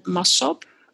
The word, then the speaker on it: Massop: Annie, it's A-N-N-I-E. And Massop, I pronounce it on Dutch, is M-A-S-S-O-P.